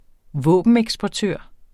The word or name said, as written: våbeneksportør